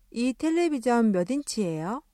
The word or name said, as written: 텔레비젼